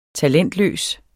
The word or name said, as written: talentløs